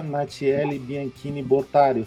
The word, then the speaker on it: Natiele Bianchini Botari: Hoje eu estou com a professora doutora Natiele Bianchini Botari. Falei certo o nome? – Sim.